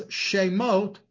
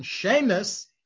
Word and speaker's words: shemot: referred to as shemot or using the Yiddish pronunciation, shemes, than as geniza.